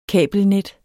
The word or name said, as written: kabelnet